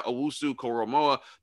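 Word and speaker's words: Owusu-Koramoah: Now, the top guys, Michael Parsons, Jeremiah Owusu-Koromoa. That's how you say his name. I actually got that right because he was a beast.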